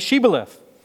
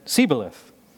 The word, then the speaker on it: Shibboleth: And when he said, "No," they said, "Well then say Shibboleth." And he said, "Sibboleth," for he couldn't pronounce it right.